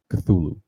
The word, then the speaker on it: Cthulhu: Specifically, we're going to be talking about his most famous work, Call of Cthulhu, which is, I guess, according to the lore, that's about as human of a way as to pronounce it, Cthulhu, because it's actually a sound made by like this being, this monster.